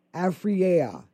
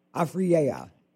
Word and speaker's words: Afriyea: Now, that is spelled A-F-R-I-Y-E-A. Afreya. Yeah, A-F-R-I-Y-E-A. Golf Academy. Afreya.